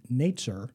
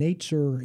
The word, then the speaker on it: netzer: Well, the term for the branch is the Hebrew word netzer for branch, and netzer in Hebrew sounds like the word for Nazarene.